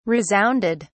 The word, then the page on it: resounded: British English